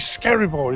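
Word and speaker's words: Skerryvore: Is Skerivor, is that how you pronounce it? – That's it, you've nailed it.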